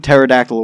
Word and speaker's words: pterodactyl: Latinum Pterodactyl Award because the P is silent.